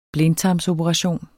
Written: blindtarmsoperation

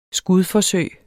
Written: skudforsøg

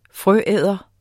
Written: frøæder

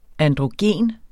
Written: androgen

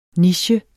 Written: niche

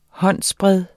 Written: håndsbred

